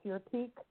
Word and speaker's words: Furtique: You spell it F-U-R-T-I. It's called Furtique. F-U-R-T-I-Q-U-E.